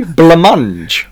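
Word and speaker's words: blancmange: Would you say blamange rhymes with orange? Orange? – But this is what... – It's a hard G. Blamange is quite a soft end. You know, orange is like quite hard.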